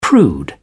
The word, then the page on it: prude: British English